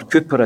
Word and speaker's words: Cowper: Cowper, or Cooper as some people pronounce his name, wrote some of the greatest hymns in Christian history, yet suffered from the deepest depressions and frequent attempts at suicide.